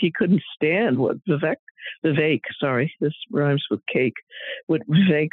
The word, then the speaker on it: Vivek: No, it's Vivek. Rhymes with cake.